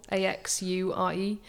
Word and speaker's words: Axure: Axure, AXURE.